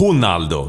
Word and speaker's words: Ronaldo: Ja, men alltså, vi har en kollega som fick nys på att den gamle Ronaldo, på portugisiska ska uttalas Ronaldo.